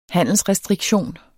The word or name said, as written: handelsrestriktion